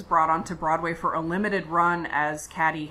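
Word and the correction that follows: Cady: Katie, yes, I'm sorry. Caddy is the way to not pronounce her name from the film.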